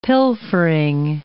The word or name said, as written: pilfering